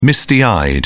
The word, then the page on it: misty-eyed: British English